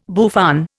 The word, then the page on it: bouffant: Indian